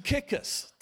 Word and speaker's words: Tychicus: if you say, How do I say that? I looked it up for you. I was just going to call him Ty, but it's to To kick us.